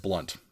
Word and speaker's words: Blount: I read a book a number of years ago by a gentleman by the name of Jeb Blount, B-L-O-U-N-T, Jeb Blount, spelled like Blount.